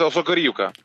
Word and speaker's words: Осокорівка: Тільки єдине село називається Осокорівка. – Осокорівка. Це дуже важлива деталь з наголосами.